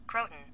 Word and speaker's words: Croton: Croton. – Croton. – Croton. – Croton. Okay. That's not as fun as crouton.